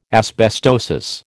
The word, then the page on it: asbestosis: American English